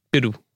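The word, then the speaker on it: Piroe: I've just realised to call him Pirro. We did the pronunciation, didn't we? – Pirro. Yeah.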